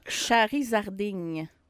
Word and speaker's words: Charizarding: Charizarding. Ah, vraiment comme ça se prononce, là, avec un Z au milieu, Charizarding.